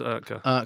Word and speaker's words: Oetker: I'm glad that you can pronounce that, because I always said Oetker, and I don't know how you say that. – No, it's definitely Dr. Oetker.